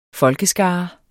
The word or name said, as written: folkeskare